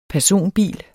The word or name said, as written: PERSONBILER